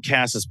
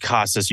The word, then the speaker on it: Casas: Yeah, I call him Casas, but it's pronounced Casas. You did have it right.